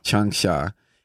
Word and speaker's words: Changsha: And there's a native of the Chinese city of Changsha. Am I saying that right? – Changsha.